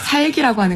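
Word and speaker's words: 삵: 삭이라고 하는 거군요. 네. – 발음은 그렇습니다.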